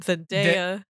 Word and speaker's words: Zendaya: Zendaya. – Yep. Look at it. It says day.